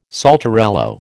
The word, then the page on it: saltarello: American English